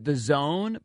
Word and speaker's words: DAZN: They pronounce it the Zone, but it's like D-A-Z-N.